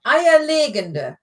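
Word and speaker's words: Eierlegende: Eier legende, not legende. Legende, if you put the emphasis on the second syllable, so legende, that would be a legend. But this That's is legende, eier legende, which is an egg laying.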